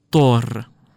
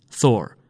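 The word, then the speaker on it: Thor: اسم خدای تندر و طوفان توی زبانهای نوردی تقریبا اینطور تلفظ میشه، تور، ولی مثلا توی زبان انگلیسی چیزی که ما میگیم اینه، ثور.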